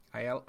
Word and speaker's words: Aiello: Danny Aiello, is that right? – Aiello. – Is it two L's? Do you pronounce that in Italian? You say Ello?